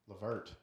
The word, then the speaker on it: LeVert: LeVert.